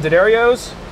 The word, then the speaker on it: D'Addario: We're using D'Addario's.